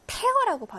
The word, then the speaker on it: tear: 자, 우리 tear는 눈물이지만 이건 동사로 tear라고 발음을 해요. tear, tear.